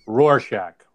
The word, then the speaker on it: Rorschach: It's a war shack test. You moron. At least I think that's what it's how it's pronounced. Is it Warshack? Rorschach. – Rorschach.